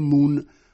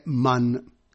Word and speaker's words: Mun: Nella traduzione si è scelto di lasciare Mun, come di solito si rinviene nei testi inglesi. Si avverte il lettore italiano che però l'esatta pronuncia thailandese è Man.